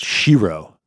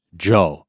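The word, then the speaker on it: shiro (城): And in Japanese, castle is pronounced shiro, but if you attach that onto the end of a word, it's pronounced jo. So like Osaka Castle is going to be called Osaka-jo in Japanese.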